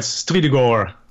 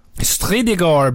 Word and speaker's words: STRYDEGOR: Is it STRYDEGOR? – Yes, that's right. STRYDEGOR. – STRYDEGOR.